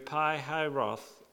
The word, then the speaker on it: Pi Hahiroth: Pi Hahiroth, opposite of Baal Zephon. And that's exactly the right way to pronounce those two places, in case you're wondering.